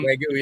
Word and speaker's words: Wagyu: Wagyu, yep.